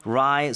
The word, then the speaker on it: wry: So crack a wry smile는 어떤 미소를 짓는 겁니까? – Very good question. It's spelled W-R-Y. So it's wry.